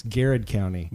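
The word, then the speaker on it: Garrard: But it's spelled Girard County. – Right. It's Garrett County.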